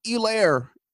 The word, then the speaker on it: Helaire: But yeah, all the announcers could do was highlight that the H is silent. H is silent. So, you know, Helaire, like emphasis.